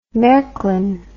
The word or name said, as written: marklin